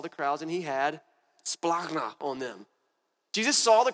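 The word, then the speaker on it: splagna (σπλάγχνα): And if you kind of say it like you're a little bit angry and sick in your stomach, it's better like splagna. It's like splagna. What does that mean?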